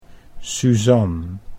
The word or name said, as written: Suzan